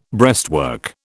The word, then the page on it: breastwork: British English